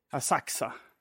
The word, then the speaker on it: Asakusa: Som man säger, för jag har lite svårt att fatta när man ska uttala U, uh, U eller inte. Alltså när, för vi bor nu i Asaxa. Mm. Men det stavas Asakusa. Ja, just det. Det är så himla konstigt.